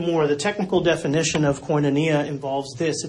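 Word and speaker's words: Koinonia (κοινωνία): Is this word pronounced koinonia? Koinonia.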